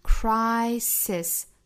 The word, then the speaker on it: crisis: crisis Crisis，中间的这个字母 I 要读成一个双元音 I 的发音， Crisis Crisis，不要读成 Crisis、啊、后面这个是单元音 A， Crisis Crisis， Come 加 S 读成 Comes Comes，尾部的这个 S 要读成浊辅音 Z，再和后面的 Opportunity 连读， Comes Opportunity Comes Opportunity。